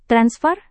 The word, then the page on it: transfer: Indonesian